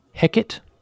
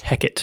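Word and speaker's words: Heqet: H-E-Q-E-T. H-E-Q-E-T. Hecate. – Yeah, that's got to be how it's pronounced. – Hecate.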